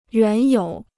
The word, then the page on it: 原有: yuán yǒu